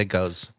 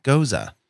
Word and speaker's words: Gose: One is G-E-U-Z-E. – Yeah. – And then that's pronounced goose. – Yes. This is G-O-S-E. And peasants in bars might say goes. Now you know better. – Don't say goes. – Goza.